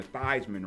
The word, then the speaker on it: Theismann: Nathan, he went to Notre Dame, had a chance to win the Heisman Trophy, so he changed his name from Theismann to Theismann to rhyme with Heisman.